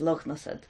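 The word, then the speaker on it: Lughnasadh: An old Irish that'd be pronounced Lugnasad.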